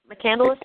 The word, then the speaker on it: McCandless: But Chris McCandless, is that how you say his name? Is that right? – I think it's McCandless. McCandless, yeah.